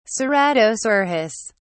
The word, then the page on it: ceratosaurus: British English